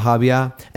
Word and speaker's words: Hobyah: So anyway, I had never heard of a Habya. And by the way, it's spelled H-O-B-Y-A-H. And I watched a bunch of different things to determine that it's pronounced Habya. But because I thought it was Habya.